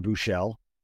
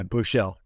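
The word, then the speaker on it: Buechele: But they got Shane Buschelli as well, or Buschel. He's a Texas guy. How do you say his last name? – Yeah, Buschel.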